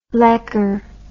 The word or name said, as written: lekker